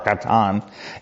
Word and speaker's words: chathan: The word bridegroom there is actually a word, it's hatan, or C-H-A-T-H-A-N, but it's pronounced hatan or katan.